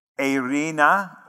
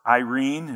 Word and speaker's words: eirene: Grace is the term charis, or the word charis, and peace is the word eirene, or Irene, if you want to go with the English pronunciation of it.